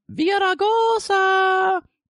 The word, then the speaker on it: Villaraigosa: Occupy LA got kicked out this week Villaraigosa. The good mayor changed his tactics. That's the way he wants you to pronounce it, by the way. We've been over this. Um, Villaraigosa.